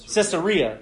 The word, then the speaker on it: Caesarea: And so he's a guy, he's from Caesarea. Is am I saying that right? Caesarea. There it is.